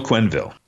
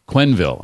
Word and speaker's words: Quenneville: So I'll just say, how do you pronounce Joel's last name? – Joel Quenneville. – Quenneville. I would have thrown an extra syllable in there. I would have gotten it wrong.